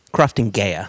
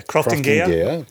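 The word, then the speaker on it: Croftengea: It's the Crofton Nagia. Am I pronouncing it right? – Oh. – Crofton Gaea. – Yeah, Crofton Gaea.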